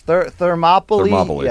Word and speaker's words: Thermopylae: What was that thing I butchered the last time? Thermopile or something. – Yes, Thermopylae. Yes, sorry.